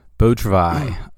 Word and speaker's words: Beau Travail: So, what did you think about Beau Travail? – Beau Travail. First of all, that's not how I pronounced it, but happy to know that I was wrong.